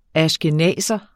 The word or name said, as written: ashkenazer